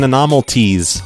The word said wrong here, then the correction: anomalies: on the last episode because I was saying the cacti were scanning their bodies for anomalies, and I honestly thought that's how it was pronounced.